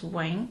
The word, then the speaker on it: Yun: My Chinese name is Wing Su. I know it's quite difficult for English British people to pronounce Wing, Y-U-N.